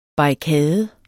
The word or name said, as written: barrikade